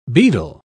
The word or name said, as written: bedel